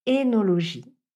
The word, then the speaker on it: œnologie: Je voulais également attirer votre attention sur un autre terme, le mot œnologie. L'œnologie qui s'intéresse à la fabrication, aux techniques, à la culture du vin, il faut en fait le prononcer œnologie.